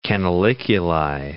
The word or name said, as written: canaliculi